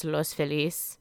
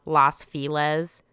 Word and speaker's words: Los Feliz: It's Los Feliz, the happy, not Los Feliz.